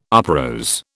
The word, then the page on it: uprose: British English